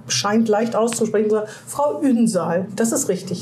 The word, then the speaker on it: Ünsal: Und Ünsal, da betonen wir Betonung ah, ja. liegt auf Sal.